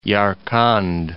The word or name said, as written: Yarkand